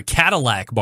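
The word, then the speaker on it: Cattleack: Did I say Cattleback? Sorry, Cadillac Barbecue. And it sounds like a car, but it's actually like, you know, cattle and then ack, A-C-K.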